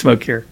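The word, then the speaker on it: Mokyr: I think it's Mokeer.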